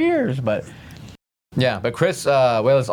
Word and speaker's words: Walas: I always thought for years and years and years and years his name was Wallace, but it's actually pronounced Wayless, which I didn't know until recently doing the research myself.